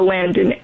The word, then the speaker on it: Landen: Or if you want to find me anywhere else on the internet, I use the name username Thea Landon everywhere, and that's spelled L-A-N-D-E-N.